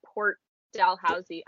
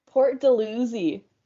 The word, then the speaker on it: Port Dalhousie: I'm going to pronounce it like Dalhousie. How did you pronounce it? – Port Dalhousie. – Port Dalhousie. – Yeah, I don't know if it's just like a local thing or if I've just been taught that it's that way and it's totally wrong, but yeah.